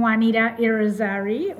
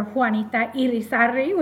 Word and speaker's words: Juanita Irizarry: I'm Juanita Irizarry, or Juanita Irizarry, whichever one you can pronounce.